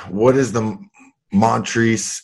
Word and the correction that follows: Piedmontese: Is it what I'm saying that right? Montrese? – Piedmontese. – Piedmontese.